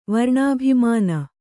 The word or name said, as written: ವರ್ಣಾಭಿಮಾನ